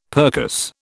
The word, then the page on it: percuss: British English